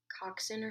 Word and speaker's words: coxswain: I think you might be pronouncing that wrong. – Pronouncing what wrong? – I think it's like coxswain or something like that. – Is it? – I don't know. How do you spell it? – C-O-X-S-W-A-I-N.